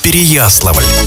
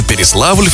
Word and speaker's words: Переславль: Изначально его название звучало как Переяславль. Произношение изменилось на Переславль в 15 веке.